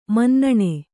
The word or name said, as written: ಮನ್ನಣೆ